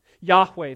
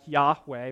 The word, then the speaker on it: Yahweh: God identifies himself as YHWH, and we think that it's pronounced something like Yahweh, but nobody knows for certain because the original Hebrew lacks the vowel pointers.